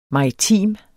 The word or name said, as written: maritim